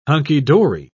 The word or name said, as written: hunky-dory